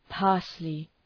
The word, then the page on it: parsley: {‘pɑ:rslı}